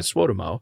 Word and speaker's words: Suotamo: I should point out, too, that Jonas Suotamo, which I finally learned how to say his last name correctly, is apparently Suotamo.